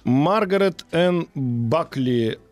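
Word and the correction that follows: Bulkley: Балкли, точнее, Балкли, правильно говорить да.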